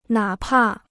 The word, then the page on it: 哪怕: nǎ pà